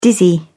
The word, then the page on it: dizzy: British English